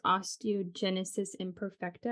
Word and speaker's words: osteogenesis imperfecta: So that's osteogenesis imperfecta. I hope I'm pronouncing that right. But yes, that's perfect.